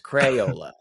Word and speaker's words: Crayola: Easy. Crayola. I had to really try on that. Crayola. Yeah. It's like payola, but with a cray. It's Crayola. It's Crayola right.